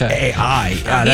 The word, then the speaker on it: Pichai: It is Pichai.